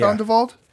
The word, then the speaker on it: Tondevold: So in comes this fella named Dan Tondevold. Tondevold? Yeah. Did I say it right? Yeah. Tondevold? – I think so. I think you got it.